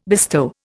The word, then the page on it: bestow: Indian